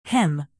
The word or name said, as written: hymn